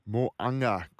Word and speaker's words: Moaga: Moaga.